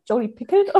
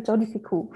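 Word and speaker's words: Jodi Picoult: Jodie Pickelt oder so, ganz falsch. Ich glaube, sie hatte recht. Also Jodie Picou.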